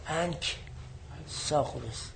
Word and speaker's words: انکساغورس: و انهو یعنی انکساغورس، کاف انکساغورس ساکنه، کافی ساکنه. ساکن تلفظ میکنم، حس میکنی تلفظ نشد.